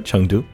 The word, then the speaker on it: Chengdu: Chengdu. – Yeah, that's right, Chengdu.